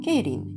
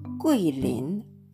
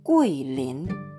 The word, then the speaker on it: 桂林: で、読み方がね、日本語とは変わってくるんですが、ケイリン、読み方は、グイリン、グイリンというふうに言います。